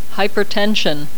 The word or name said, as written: hypertension